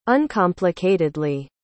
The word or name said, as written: uncomplicatedly